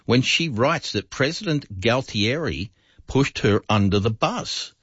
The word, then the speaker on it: Galtieri: G-A-L-T. – Oh, Galtieri. Yeah. – Galtieri.